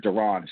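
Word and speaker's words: Da'Ron: And then you look on the defense and it's Matt Ioannidis, it's John Allen, it's De'Aaron. Or Deron, excuse me, didn't mean to pronounce his name wrong.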